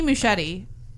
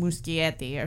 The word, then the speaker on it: Muschietti: So Andy Muschietti, who is Argentinian, I always thought he was Italian, and I always want to say his name, Muschietti or something like that, but it's Muschietti.